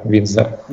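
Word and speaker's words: Windsor: Винсдер, да, правильно говорить? Винсдер, Винсдер. – Не, Винсдер. Без Д.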